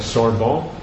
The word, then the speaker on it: Sorbonne: And I think it was, I'm not sure how you say it, it's something like Sorbonne? – Okay. – Yeah. Is that how you say it? – Yeah. – This is Sorbonne.